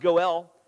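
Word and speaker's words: goel (גואל): Kinsman redeemer comes out of a term, a word pronounced goel.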